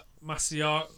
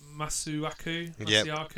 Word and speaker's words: Masuaku: Salah goes down after Masuaku. Masuaku, is that you say his name? – Yeah, I think so.